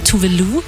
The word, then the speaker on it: Tove Lo: Aber sie hat uns eine kleine Eselsbrücke gegeben, nämlich Voulez-vous, also Französisch, Voulez-vous, wollen Sie? Voulez-vous, Tove Lo?